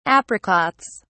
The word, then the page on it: apricots: British English